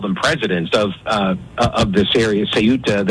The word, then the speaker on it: Ceuta: So I was like, well, Ceuta. And the pronunciator online told me it's Ceuta.